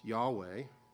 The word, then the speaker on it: Yahweh: There were no video recordings back then, or audio recordings, so we don't know how they pronounced it, but we can guess relatively intelligently that it was pronounced and should be pronounced Yahweh.